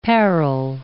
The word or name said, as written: parol